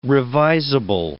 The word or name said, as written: revisable